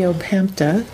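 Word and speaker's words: upphämta: The opemta, I finally said it right.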